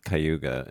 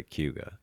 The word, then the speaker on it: Cayuga: I always thought it was Cayuga, and then I started hearing locals say Cayuga. – Yes, that's how that is how we pronounce it.